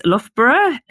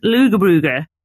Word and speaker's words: Loughborough: One very famous place in the UK is Loughborough, which is often pronounced as Lugabrugger by people who do not know how to pronounce it correctly.